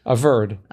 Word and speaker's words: averred: How do you say it? – Averred. – Averred.